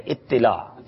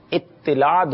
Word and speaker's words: اطلاع: جی تو پہ تشدید ہے, اطلاع. جی اطلاع درست ہے, تا پہ جزم نہیں ہے.